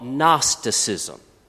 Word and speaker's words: Gnosticism: a view of the world has emerged called Gnosticism. If it's ever on the spelling bee, it starts with a silent G.